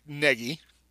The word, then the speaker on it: Nagy: And then they were coming up with other names that aren't pronounced the way everybody pronounces it, like Negi. Because I've heard that too, that he really is supposed to be Negi, which makes no sense. Why is it N-A-G-Y if it's Negi? That would just be perfect for that family, I guess.